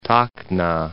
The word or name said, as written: Tacna